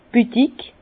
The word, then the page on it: d' Büdig: Bas-Rhin; Herrlisheim